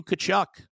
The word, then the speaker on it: Tkachuk: Led by Matthew Tkachuk.